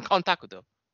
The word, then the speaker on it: Kontakuto: Kontakuto. That's what it. That's a better pronunciation. Kontakuto.